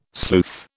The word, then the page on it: sleuth: British English